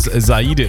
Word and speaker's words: Saide: So wie der Speaker es teilweise ausgesprochen hat. Mittlerweile weiß auch der Speaker, dass es Saide ist.